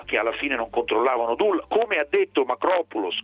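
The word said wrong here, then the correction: Markopolos: che anzi Marco Polus, perché poi il nome si pronuncia in questo modo, certo.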